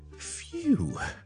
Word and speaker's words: phew: 今天讲呢就是呢，当我们发生了一些很险的事， OK，哇很险啊，好在最尾呢就没事，这样我们英文呢那个很舒缓的语气呢，我们就会呢用讲 few, OK? It sounds like F-E-W, OK? But it's spelt as P-H-E-W, 全部是 P-H-E-W, few.